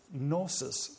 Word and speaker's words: gnosis: It's gnosis, spelled with a soft letter G, gnosis, the Gnostics, okay?